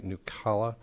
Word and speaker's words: Nucala: It's called, if I'm pronouncing this right, Nucala.